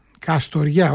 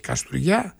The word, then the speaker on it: Καστοριά: Τρίτος όμιλος, Ξάνθη Πανθρακικός, Νάουσα Ορέστης, Καστουριά, Καστοριά, όχι Καστουριά, το είπα πολύ με προφορά, έτσι ντόπιο λαλιάς.